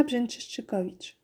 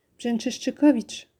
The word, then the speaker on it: Brzęczyszczykowicz: Katarzyna Brzęczyszczykowicz. Comment? A vous souhait? Ah ben merci. Brzęczyszczykowicz. C'est mon nom de famille. Brzęczyszczykowicz. Tout simplement. Comme ça se prononce. Enfin, en Pologne.